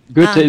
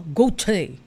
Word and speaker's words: Goethe: Goethe Institute Philippinen. – Almost po. Medyo iti-twist lang po yung O ng konti. Goethe Institute Philippinen. – Ah, Goethe.